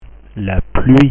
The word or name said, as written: la pluie